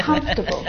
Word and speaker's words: comfortable: I've always wondered about comfortable. – That. – Now, this word, just like vegetable, has an extra vowel in there that we don't need to pronounce. Comfortable. Not comfortable.